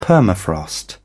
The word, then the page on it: permafrost: British English